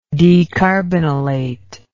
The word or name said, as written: decarbonylation